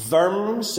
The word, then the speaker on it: Worms: Worms in Germany, or as I think apparently it's properly pronounced Worms. It's pronounced with like a V rather than a W, but the place where Martin Luther defied an empire, and we thought about that last year on the 500th anniversary.